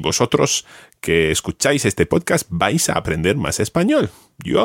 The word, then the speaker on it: vais: It's almost like one syllable. You're pronouncing it ever so slightly as two syllables there, vais. But run them together and just say vais.